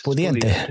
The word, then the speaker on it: pudiente: Está pundiente, ¿cómo se dice? Pundiente. Ya se me han ido. Pudiente. Pudiente. Viste que tantos años.